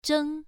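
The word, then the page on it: zheng: tone 1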